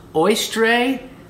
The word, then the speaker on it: Oestre: Now, there was this pagan goddess named, I'm probably not going to pronounce this right, but it's Oestre. And I'm going to spell it O-E-S-T-R-E, Oestre.